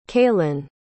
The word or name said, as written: kalen